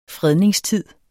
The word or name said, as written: fredningstid